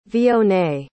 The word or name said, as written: vionnet